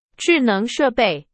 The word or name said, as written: 智能